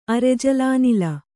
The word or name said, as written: ಅರೆಜಲಾನಿಲ